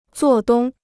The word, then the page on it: 做东: zuò dōng